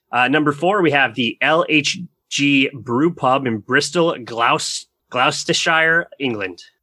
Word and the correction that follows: Gloucestershire: I'm sure I butchered that. – That sounds like a sauce. It's Gloucestershire.